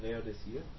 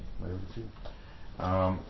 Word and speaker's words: Laodicea: My quickly, is it pronounced Laodicea? – That's right. – Okay. – Laodicea.